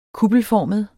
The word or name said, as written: kuppelformet